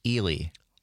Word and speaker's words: Ely: I will say, though, Phil in Minneapolis just called in and says it is pronounced Ely. – Oh, really? Ely.